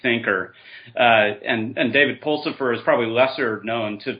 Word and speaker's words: Pulsipher: Uh, you you also worked with uh, Richard Bushman, and then there's is it, am I going to say this right? David Pulsipher. That's right.